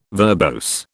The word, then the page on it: verbose: British English